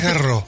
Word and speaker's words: Garros: Garros, creo que es Garros. – Sí, por la S al final en francés. – La S no se pronuncia.